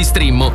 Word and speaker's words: Streammo: Entra adesso nel mondo di Strimmo. Vai su strimmo.it. Si scrive Streammo o scarica l'app.